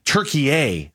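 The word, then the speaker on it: Türkiye: When we come back on the Burns and Gambo show, after I remind you that tomorrow night, USA takes on, are we saying Turkey? But that's not the correct pronunciation, right? Go ahead. – Turkey.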